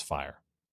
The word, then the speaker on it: FHIR: And you may be excited to know that FHIR is pronounced fire. Nobody reads the letters out.